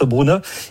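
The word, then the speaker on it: Bryne: qui s'écrit Brine en français, mais Brune, ce, qui se prononce Brune.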